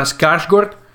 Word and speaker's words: Skarsgård: Stana Skarsgård, sì, si pronuncia così perché, perché è, è, svedese,